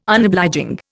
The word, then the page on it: Unobliging: Indian